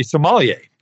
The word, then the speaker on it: sommelier: Um, first of all, you are a sommelier. Did I pronounce that right? You did, yes.